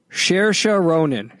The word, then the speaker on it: Saoirse: Saoirse Ronan is how you say it. Saoirse Ronan is how you say the name.